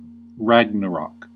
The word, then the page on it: Ragnarok: /ˈræɡ.nə.ɹɒk/; /ˈræɡ.nə.ɹɑk/